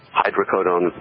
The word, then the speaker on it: hydrocodone: One of them is about the hydrocodone wars, opioid drugs, hydrocodone being one of them. – Wasn't there some approval of a new hydrocodone, how do you pronounce it? Hydrocodone? – Hydrocodone, yeah.